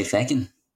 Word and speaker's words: feckin': You say feckin'? – Well, because it's feck. Arthur Fleck. Exactly. It's not. – It's not fuck. It's feck.